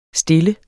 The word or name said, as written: stille